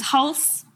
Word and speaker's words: Halse: Did I butcher that pronunciation? – I think it was very close. It's Halse, but Halse, you know, also okay. No one really knows how to pronounce that, so it's all good.